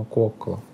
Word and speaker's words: Кокала: Это такой выступ Кокала. Кокала. Мне тут поправили, что в финских названиях все ударения на первый слог. То есть, видимо, Кокала.